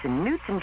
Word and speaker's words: Knutson: The K is silent, Adam. It's Knutson cheesecake dessert in a cup.